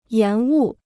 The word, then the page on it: 延误: yán wu